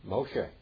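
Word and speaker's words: Moshe (משה): How do you say Moses in Hebrew? – Moshe.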